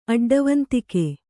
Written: ಅಡ್ಡವಂತಿಕೆ

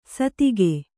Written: ಸದೆಗ

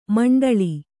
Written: ಮಿಂಡೇಳು

